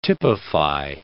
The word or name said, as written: typify